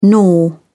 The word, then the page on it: gnaw: British English